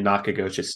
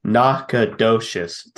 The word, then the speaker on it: Nacogdoches: Austin down in Nacogdoches, Texas. I just butchered that. Nacogdoches, Texas. – You're pretty close the first time. I mean, like, that wasn't bad at all.